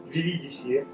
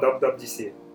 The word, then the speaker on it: WWDC: Как правильно говорит WWDC? Есть несколько вариантов. WWDC. – Вот, WWDC. Как говорит Глеб, VVDC, а, VVDC и, как говорят сами чуваки из Apple, WWDC.